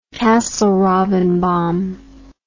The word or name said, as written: castle robin bomb